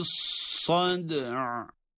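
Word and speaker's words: الصدع: Yani burada önce yine sad derken kalkale yaptık. Sat harfi kalın olarak tabii. – ذَاتِ الصَّدْعِ. – Sonra da ayn harfini cezimli olarak telaffuz ediyoruz.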